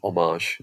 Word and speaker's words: homage: I love the way you made the H silent in homage instead of homage or whatever. You went French on me.